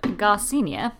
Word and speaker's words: Garcinia: Okay, I have looked up Garcinia, as the YouTube video told me is how you pronounce it. Garcinia, I don't think I was far off.